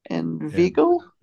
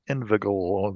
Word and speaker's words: inveigle: I-N-V-E-I-G-L-E. Inveigle? – Inveigle. Inveigle. There's only one G, so it would be a long I sound.